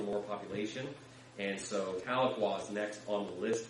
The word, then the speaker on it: Tahlequah: That's in, I hope I'm saying it right, Tahlequah. Saying that right? All right, sweet. Tahlequah, Oklahoma.